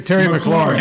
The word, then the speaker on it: McLaurin: Terry McLaurin.